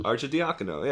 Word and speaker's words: Arcidiacono: Archidiakono.